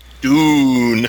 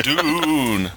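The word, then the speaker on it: DoOon: Dune. – Dune.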